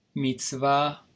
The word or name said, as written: mitzvah